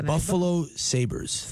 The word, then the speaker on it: Buffalo Sabres: Buffalo Sabres.